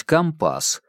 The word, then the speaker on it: компас: Точно так же морякам позволительно говорить «компас» с ударением на второй слог.